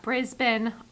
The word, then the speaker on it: Brisbane: We've got Brisbane. All of them are spelled with R's, but nobody pronounces the R's.